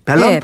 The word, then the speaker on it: 밸런타인데이: 발렌타인데이. – 예. – 외국어 표기법으로는 발렌타인 발런타인데이? – 밸런타인데이. 예, 밸런타인데이라고 발음하는게 예. 맞아요. 예.